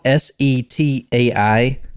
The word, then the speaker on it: Setai: S E T A I Setia Hotel. I saw the word right here. It says uh, Setia. How do you say Setai. – Setai. The Setai Hotel.